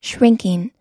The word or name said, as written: shrinking